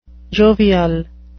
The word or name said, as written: jovial